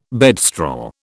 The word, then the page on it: bedstraw: British English